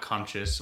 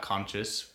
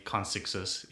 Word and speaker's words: concixus: at conscious. Well, I say at conscious, but it's spelled at C-O-N-C-I-X-U-S. – Ooh, people also say consixus, yeah.